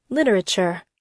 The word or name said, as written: literature